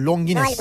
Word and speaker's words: Longines: Tesadüfen ben de Longin, bu Longin mi okunuyor? Longines. – Galiba evet.